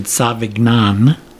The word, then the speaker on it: Savagnin: Because it's spelled very similar to Sauvignon Blanc. But I would pronounce it Savagnin.